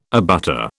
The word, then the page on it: abutter: British English